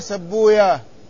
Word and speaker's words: سيبويه: هذه كلمة سيبوي عند غير المحدثين فإنهم لا ينطقون بويه. ما سمعتم هذا؟ يقولون إن المحدثين لا ينطقون بويه، ذلك أن ويه من أسماء الشياطين، وبين المحدثين وبين الشياطين عداوة، ولذلك كل اسم جاء مختوما بهكذا بويه هم ينطقونه فيقولون في سيبويه سبويا، وفي راهويه راهويا، وفي نفطويه نفطويا، وهكذا.